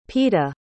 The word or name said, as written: Peeta